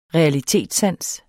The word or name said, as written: realitetssans